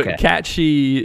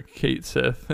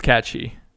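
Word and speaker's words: Cait Sith: So Kat She is how it's pronounced. Everyone and their mother called her Kate Sif, and the original called them Kate Sif.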